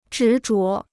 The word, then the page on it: 执着: zhí zhuó